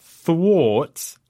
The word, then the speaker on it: thwarts: T H W A R T S, and it's the A R that makes the oh, sound.